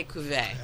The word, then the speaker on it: Cuvée: Although he makes us say Cuvée. – Oh, nuts. – Yeah, because it's Belgian. Yeah, he's Flemish. Cuvée. That's how they say it.